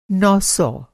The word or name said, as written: नौ सौ